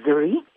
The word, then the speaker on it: Zari: Zari is, and it's in, in India they pronounce it Zuri.